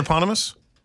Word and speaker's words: eponymous: Did I mispronounce the word eponymous? – Did I say it right that time. – Did I say eponymous? – You did. – Oh, sorry. – Eponymous. – Okay. – Like a hippopotamus.